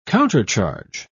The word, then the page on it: countercharge: British English